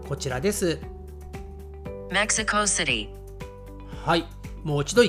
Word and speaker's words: メキシコシティ: えー、少しね、カタカナっぽい言い方にはなります。メキシコシティ。ね、シティと言わないですね。メキシコシティと日本人は発音します。